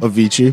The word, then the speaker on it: Avicii: So, Avicii. Is that how you pronounce his name? – Avicii.